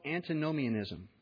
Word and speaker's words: antinomianism: It was called antinomianism. I said it right the first time.